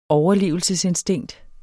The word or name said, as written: overlevelsesinstinkt